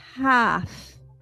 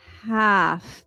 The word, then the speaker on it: half: Now, when it has the sound of a short A, it could be examples like half. Again, my mouth does not round out as much as the O. It would be half, like A, ah, half.